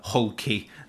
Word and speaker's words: Hulk: and I'm going to pronounce it Brazilian way, a hulky Hulk to everybody else.